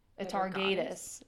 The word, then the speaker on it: Atargatis: So, how I want to say it is Atargatis, but that sounds awful. Atargatis is like how it's supposed to say.